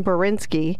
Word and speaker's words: Bourinski: And the guest this morning is Ryan Bourinski.